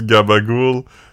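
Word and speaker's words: capicola: Euh, c'était genre si Capicolo se prononce gabagoul, le réalisateur Francis Ford Coppola devrait se présenter Francis Ford Coppola.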